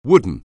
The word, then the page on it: wooden: anglais